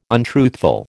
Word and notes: untruthful: American English

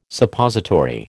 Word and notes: Suppository: American English

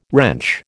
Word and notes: wrench: American English

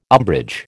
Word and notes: umbrage: American English

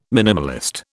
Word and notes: minimalist: British English

